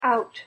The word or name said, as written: out